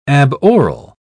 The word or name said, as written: aboral